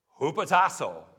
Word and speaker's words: hupotasso: but it's the word hupotasso, which is pronounced hupotasso. It's right there, right? It's pronounced hupotasso.